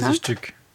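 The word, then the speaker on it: Fantasiestück: Ça se prononce comme ça? – Fantasiastück. – Voilà, merci.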